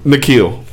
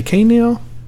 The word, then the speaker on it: N'Keal: How do you say his name? – N'Keal. – What did I say? K'Neil? Okay. Don't Number 23, Houston Texans.